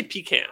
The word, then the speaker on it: pecan: Pecan versus pecan. – Oh, yeah. I say pecan. – Highly debated. Yeah. It is here, it's pronounced pecan. – Pecan.